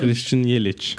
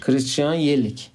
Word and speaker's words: Christian Yelich: Nasıl okunuyor bu? Christian Christian Yelich. Christian Yelich. Yelich. Aynen.